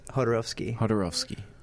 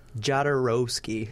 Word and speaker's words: Jodorowsky: Jodorowsky. – Jodorowsky.